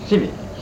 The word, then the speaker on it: civvy: How do you pronounce the civvy or skivvy? – Civvy. – Civvy cat.